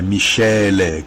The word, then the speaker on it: miscele: Michele, mas se escreve, pelo amor de Deus, não é aquele Michele, não. É Michele, que se escreve com M-I-S-C-E-L-E, que quer dizer misturas, né?